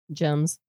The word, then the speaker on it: gems: Gems. – That's right. – Okay. Wow. Or as Steve pronounces it, yes. Gyms.